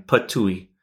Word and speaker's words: Ptuj: The heartland of it is the mellifluously named town of Ptuj, pronounced a bit like you're spitting, Ptuj.